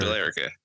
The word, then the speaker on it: Billerica: First off, it's spelled B-I-L-L-E-R-I-C-A. I pronounce it as Billerica. – It's like Bill America. America, but Bill. – Billerica. I said Billerica.